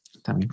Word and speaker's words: Intamin: Intamin. Klingt wie Vitamin.